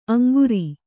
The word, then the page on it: ಅಂಗರ: aŋgara